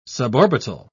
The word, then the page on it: suborbital: British English